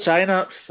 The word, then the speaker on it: Synapse: Is that, am I saying that right? – Sounds about right. Oh, was Synapse, was it? – Synapse. Oh, there you go.